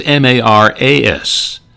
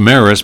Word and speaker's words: Maris: Played for Sacred Heart Parochial School in Fargo, and it was always M A R A S. And he said, "Yeah, I'm, that's our family name, and it's pronounced Maris." But he said, "I got so tired of people mispronouncing it, Maras, that I changed the spelling on my own."